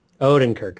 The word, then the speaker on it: Odenkirk: Odenkirk.